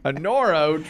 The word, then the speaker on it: Anoro: Anoro treats it's pronounced Anoro.